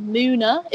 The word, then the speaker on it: MUNA: There's also a group called, I think you pronounce it Muna. It's like in capital letters, M-U-N-A.